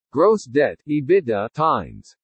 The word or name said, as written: gross debt/EBITDA times